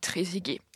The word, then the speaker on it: Trezeguet: Et c'est un clin d'œil un peu spécial aujourd'hui, puisque sans vanne, promis, je vais rendre hommage à David Trezeguet, qui fut un très grand attaquant.